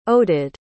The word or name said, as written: oded